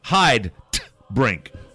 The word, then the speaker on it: Heidebrink: Let me pronounce it the proper German way. – Good, thank you. – Heidebrink. – Nice.